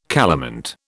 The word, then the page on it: calamint: British English